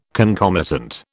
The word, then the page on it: concomitant: British English